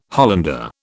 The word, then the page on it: Hollander: British English